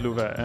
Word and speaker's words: Love: But, so my name is in Swedish. It's Love. Uh-huh. And it's spelled like love. – Like the English L-O-V-E. Word love, right. – Yeah, right. – And it's pronounced Luva.